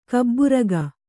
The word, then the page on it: ಕೆಬ್ಬೆರೋಗ: kebberōga